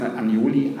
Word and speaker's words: Agnoli: du hast ihn Agnoli ausgesprochen, er heißt Agnoli, aber er hieß in der Wehrmacht tatsächlich, wurde sein Name germanisiert, da hieß er tatsächlich Johann Agnoli.